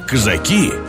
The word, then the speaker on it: казаки: Его казаки, именно так нужно ставить ударение, действительно сражались с французами в составе партизанского отряда Дениса Давыдова.